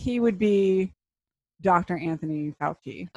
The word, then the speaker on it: Fauci: You actually said this name earlier, which makes me think I'm pronouncing it wrong, but, you know, Dr. Anthony Fauci. – Fauci.